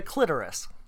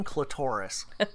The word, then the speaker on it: clitoris: The clitoris. And you know what, people? Stop saying clitoris. I think that was a Seinfeld thing. Like Dolores, it had to rhyme with Dolores.